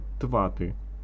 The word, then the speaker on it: ТОЭТ: Семейство этих наркотиков мы называем ТОМы и ТВАТы. Подождав, пока стихнут охи и ахи, Шура объяснил. Название последнего на самом деле пишется как ТОЭТ. Потому что это серийный аналог ДОЭТ. Но произносить его можно как ТВАТ.